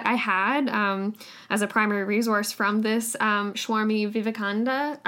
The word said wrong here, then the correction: Vivekananda: I'm not saying that right. I feel like it's Vivekananda. That's it. Sorry. – Yeah, Vivekananda. – I'm missing a syllable again.